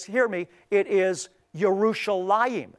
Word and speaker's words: Yerushalayim: as I indicated is in the case with Yerushalayim, which is the correct way to say Jerusalem, versus Jerusalem.